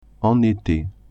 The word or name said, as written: en ete